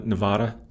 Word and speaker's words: Nevada: Nevada mm-hmm did I say that correctly? N- Nevada. Yeah, that's fine.